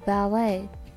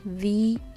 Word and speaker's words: Valet: 旅行されるっていう方は車を預けたりすると思うんですけどその時に鍵を預けて車を動かしてくれる人のことをバレーと言います V 最初は V ですね VALET で Valet と言います最後の T は発音しません Valet 多分これフレンチだと思うんですけどえっと、その人にはだいたい 2 ドルから 5 ドル払ってあげてください。